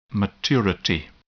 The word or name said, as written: maturity